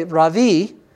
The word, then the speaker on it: ravi: It comes from the word rav, R-A-V, you would say it in English. Rav means great. And when you add that I on the end, you would say ravi, that would mean my great one.